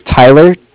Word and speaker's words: Tyler: My name is Tyler, T-Y-L-E-R, and I'm calling from Cardiff. It's spelled C-A-R.